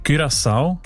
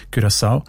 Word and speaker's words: Curaçao: Curaçao ist das Land, habe ich gelernt. Spricht man eben mit «ü» aus, weil Holländisch dort auch eine wichtige Sprache ist. Und im Holländischen ist das «ü» offenbar häufig als «ü», Curaçao.